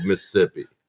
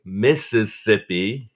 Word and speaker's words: Mississippi: I want our listeners to really focus on the fact that Doug is not saying Mississippi. He's saying Mississippi. – You have to skip a syllable.